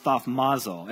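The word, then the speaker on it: thaumazo (θαυμάζω): And so the word marvel in Greek is pronounced thaumazo. Everybody say thaumazo.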